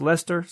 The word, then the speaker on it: Leicester: A listener named Simon Edwards wrote in and said, dear Rogues, my name is Simon from Leicester, pronounced Lester. Sorry. Okay. – Leicester. I think it's Leicester. – It's Lester. Lester.